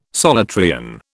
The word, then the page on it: Solutrean: British English